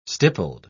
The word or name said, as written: stippled